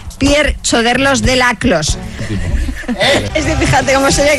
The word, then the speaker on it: Choderlos de Laclos: Hola, se dice Pierre Choderlos de Laclos.